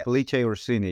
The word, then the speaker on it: Felice Orsini: Felice Orsini, yeah.